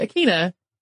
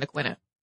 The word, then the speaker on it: Aquinnah: Before anyone writes in, I have heard humans pronounce these as Wampanoag and Aquinnah. However, the far more common pronunciation that I found, including people that I checked with directly, were Wampanoag and Aquinnah.